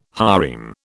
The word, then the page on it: harem: British English